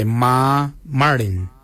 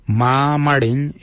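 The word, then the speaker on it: marrmardiny: Ma kuju is spelled M-A-R-R, then it's G-O-O-D-J-A-L. For 8 we say ma marin. Ma marin is number 8. Ma is spelled M-A-R-R, then it's M-A-R-R-D-I-N-Y. Ma marin for number 8.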